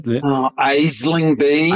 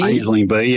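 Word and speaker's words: Aisling Bea: How do you pronounce the name? – Aisling B. – Aisling B, yeah, the sort of Irish actor.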